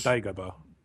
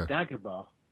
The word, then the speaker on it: Dagobah: But, how do you say that planet name? – I would have said Dagobah.